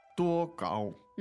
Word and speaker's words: 多高: 多高. – There you go. Let's repeat that a few times. It basically means how tall. – Yeah. 多高. – Two first tones, right? – Correct. – 多高.